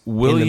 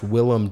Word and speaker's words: Willem: Willem Dafoe plays Christ William? – In the movie. Willem Dafoe. – Wait, it's not William. – No, it's not William. – I thought it was William. – Technically, it's pronounced Willem Dafoe.